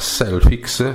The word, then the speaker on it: Selfiex: L'applicazione di cui vi parlo si chiama Selfix, si scrive S-E-L-F-I-E-X